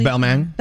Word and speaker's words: Balmain: Apparently it was Balmain. Am I pronouncing that correctly? – Balmain.